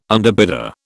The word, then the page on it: underbidder: British English